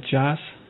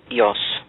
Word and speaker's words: Jos: J-O-S. Is that Joss? – Yos.